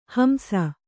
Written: ಹಿಂಸೆ

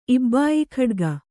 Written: ಇಬ್ಬಾಯಿಖಡ್ಗ

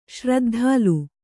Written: ಶ್ರದ್ಧಾಲು

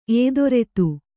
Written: ಏದೊರೆತು

